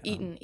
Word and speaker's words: Eaton: Eaton, E-A-T-O-N.